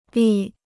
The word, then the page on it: 力: lì